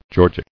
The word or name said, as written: Georgic